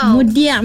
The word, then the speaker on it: Mudiak: Mudiak. – Macam mana sebut? – Mudiak. Tapi ejaan itu K Mudia.